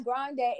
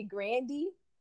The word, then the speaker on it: Grande: And her name is Grande. It's actually Grandy. I saw a whole interview about that, and everybody was like, okay, because she pronounced it Grandy, because that's her, you know, her grandfather's name.